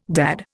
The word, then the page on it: dad: Indian